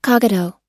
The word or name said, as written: cogito